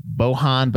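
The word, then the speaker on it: Bojan: Cinematography by Bohan Bazelli. I don't think you pronounced the J there.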